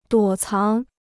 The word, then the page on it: 躲藏: duǒ cáng